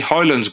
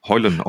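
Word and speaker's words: Højlund: Hoyland's goal was Hoyland. Because you don't pronounce the D, apparently. – Hoyland.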